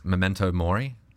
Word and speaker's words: memento mori: So one of them, for example, that's pretty famous, I hope I'm saying this right, is memento mori.